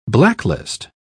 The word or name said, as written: blacklist